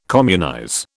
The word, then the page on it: communize: British English